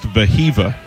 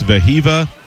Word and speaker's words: Wehiwa: Cahillo's older brother's name is pronounced Vahiva. – Vahiva. – It's spelled W-E-H-I-W-A. They're both from Hawaii, and it's Vahiva and Cahillo Aloy.